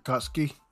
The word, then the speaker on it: Akatsuki: Akatsuki, I want to say that's how it's pronounced. – Akatsuki. – Okay, Akatsuki, there you go.